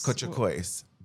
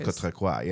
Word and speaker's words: Cortocois: Cortocois. But in French, it's Cortocois. Yeah. – Okay. Okay. – But we could go either way.